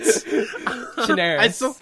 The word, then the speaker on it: Chinaris: Chinaris. But close Chinaris.